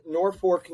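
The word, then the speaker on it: Norfolk: It's called North Fork, Nebraska, but it's spelled with an L.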